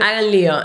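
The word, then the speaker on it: hagan lío: Hug on Leo, is that how you say it? – Hug on Leo. And I remember I was trying to translate that word.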